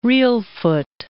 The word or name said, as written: Reelfoot